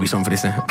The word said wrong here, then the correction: Bichón frisé: Bichón Frisé.